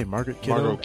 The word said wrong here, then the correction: Margot Kidder: Margot Kidder. – I butchered her name. Margot Kidder.